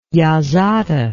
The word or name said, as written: yazata